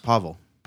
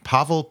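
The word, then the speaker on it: Paweł: I think it's pronounced Pavel. – You're right, Pavel Pogorzelski. That's the best Pavel I'm gonna do.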